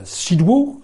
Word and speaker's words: Szydło: C'est alors que la première ministre de Pologne, Madame Beata Szydło, euh, je ne suis pas sûr de bien prononcer, mais je sais que le, L barré se prononce W en, en polonais,